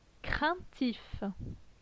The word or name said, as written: Craintif